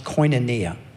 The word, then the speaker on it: koinonia: Now, this word fellowship, it is the translation of a Greek word that is pronounced koinonia.